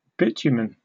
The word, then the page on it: bitumen: British English